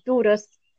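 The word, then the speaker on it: Durrës: Durres.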